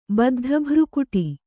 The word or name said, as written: ಬದ್ಧಭ್ರುಕುಟಿ